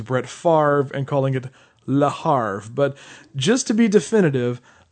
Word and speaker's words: Le Havre: So it's Le Havre.